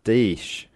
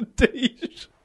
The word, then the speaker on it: Deesh: Deesh. – Fuck is a dish.